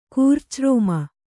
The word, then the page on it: ಕೂರ್ಚರೋಮ: kūrca rōma